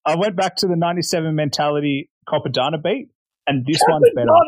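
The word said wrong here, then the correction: Cappadonna: He's definitely not. He's butchering it. I'm not. I'm butchering it. – Yeah. – Yeah. The the Mentality Capadonna beat. – You got it. You did it right.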